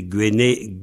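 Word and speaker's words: gwennel: Alors, on dit Guenel avec l'accent au début, avec deux N, donc le E, bref, Guenel, Guenel.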